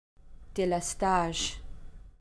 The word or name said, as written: delestage